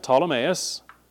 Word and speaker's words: Ptolemais: When we had finished the voyage from Tyre, we arrived at Ptolemais. Young people looking at that word, don't even try to pronounce that P. It's not really there. It is, but it's not.